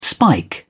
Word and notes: spike: British English

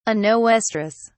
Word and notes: anoestrous: British English

